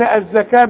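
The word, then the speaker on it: الزكاة: الزكاه، كل الزكاه بتاعه القران مكتوبه بالواو لكن تنطق بالالف، الزكاه، ورسم لك الفين. ثم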